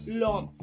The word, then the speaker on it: l'homme: The homme, which means man in French, starts with the letter H and it is a silent H. So the um, E in le is swallowed and is pronounced l'homme.